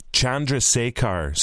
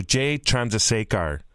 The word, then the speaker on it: Chandrasekhar: Chandrasekhar. So Jay Chandrasekhar.